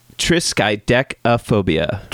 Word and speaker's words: triskaidekaphobia: It's tryptodecaphobia? – No. – Did I get it right? No. – It's... Triskaidekaphobia. – Triskaidekaphobia.